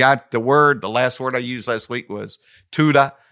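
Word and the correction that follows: Toda (תודה): Actually, it's pronounced Toda.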